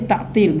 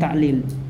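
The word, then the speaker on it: ta'til (تعطيل): Wa tarakul qawla bitta'til, afwan, namanya ini ta'til bukan ta'lil.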